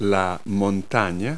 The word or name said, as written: mountain